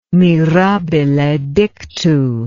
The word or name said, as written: mirabile dictu